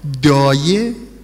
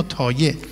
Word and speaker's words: دایه: دایه، میخون تو اون شعر لوری زیبا، دایه شما تلفظ بعدیش چیه؟ تایه. کدوم درسته؟ دایه یا تایه؟ یعنی بین دال و ته کدوم درسته؟ هر دو درسته.